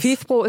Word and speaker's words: FIFPro: Wie spricht sich das aus? – FIFPro ist komplett richtig.